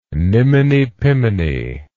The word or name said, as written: niminy piminy